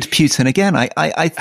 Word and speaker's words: Putin: It's Putin, not Putin.